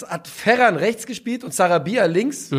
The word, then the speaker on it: Sarabia: Ähm, und ich glaube, dass, äh, wie spricht man aus? Sa Sar Sarabia? Sarabia, ich wollte Sarabi sagen.